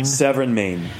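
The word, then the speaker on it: Sèvre et Maine: So when a bottle says, and help me on my pronunciation, Sèvres et Maine? – Sèvres and Maine, yeah.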